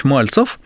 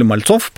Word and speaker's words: Мальцов: Итак, первый паровозостроительный завод в России основывает Сергей Иванович Мальцев, или Мальцов по-другому фамилию произносит, это Людиновский заводской округ.